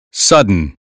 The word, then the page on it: sudden: American English